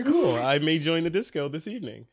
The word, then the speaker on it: DSCO: And they do confirm that it's pronounced DSCO in their email.